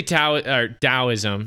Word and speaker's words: Taoism: But mostly Taoism. That's how you pronounce it, right? Like a D? – Yes.